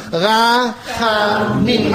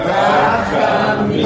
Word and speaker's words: Rachamim: Rachamim. Alle. – Rachamim.